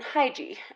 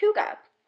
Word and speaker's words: hygge: Because it's spelled H Y G G E. And to me, I thought that meant hygie. And I was like, that's kind of a weird name for a store or cafe. Um, and he said, no, it's actually Danish. And the word is pronounced huga.